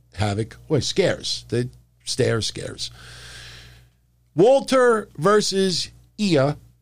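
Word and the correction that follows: Ilja: Dragunov. Did I say that right? See, I got to get used to it now. Ilya.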